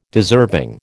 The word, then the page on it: deserving: American English